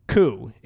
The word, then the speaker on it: Koo: Is how you pronounce his first name. Koo is his last name. Ko K K O O.